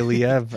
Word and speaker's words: Liev: Happy birthday, Liev. I think that's how you say it. L-I-E-V. – L-I-V-E. – L-I-E-V. – Oh, L-I-E-V. – Yeah. – I was like, live? Or live?